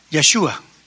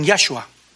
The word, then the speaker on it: Yeshua: Entonces vamos a ver la palabra en el nombre del Señor Jesús, Yeshua. Yeshua significa salvación. Incluso algunos pronuncian Yahshua de Josué, que es salvación.